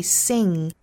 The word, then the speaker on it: sing: 那比如说像 S-I-N-G, sing, 包括像 S-O-N-G, song, 这些都是包含后鼻音的。所以读的时候不能读成 sin 或者是 son, 应该是 sing 和 song。